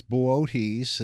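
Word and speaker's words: Boötes: Yeah, that's in the constellation of, I like that you said Boots. It's Bootes, is often pronounced, but who would know how to spell B-O-O-T-E-S?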